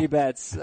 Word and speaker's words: Mookie Betts: Is it Mookie Betts? – His name rhymes with Kookie Metz. Yes, yeah. – Mookie Betts.